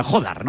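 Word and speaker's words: Jódar: Ha pegado un ace, pero vamos, es una de sus bazas hoy para frenar el buen juego de inicio de Jodar, como hay que pronunciar. – Hay que poner Jodar, ¿no? – Con la tilde de la O.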